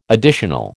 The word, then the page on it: additional: American English